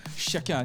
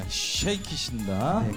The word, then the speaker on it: Shake It: 네, 발음을 주의하셔야 됩니다. 쉐키 아닙니다. 쉐이킷입니다. 네, 그 썩는다고 그 쉐이킷 맞죠? – 네.